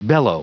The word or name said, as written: bellow